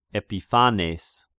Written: Epiphanes

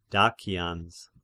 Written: Dacians

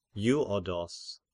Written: Evodos

